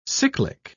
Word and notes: cyclic: English; British English

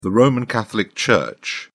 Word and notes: roman catholic church: British English

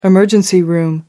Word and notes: emergency room: American English

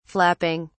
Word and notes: flapping: British English

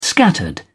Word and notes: scattered: British English

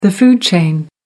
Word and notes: food chain: American English

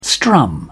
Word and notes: strum: British English

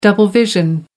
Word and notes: double vision: American English

